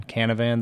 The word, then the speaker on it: Canavan: I was a basketball player, and my last name rhymes with Banana Man, and all my friends called me Banana Man. Canavan, the Banana Man.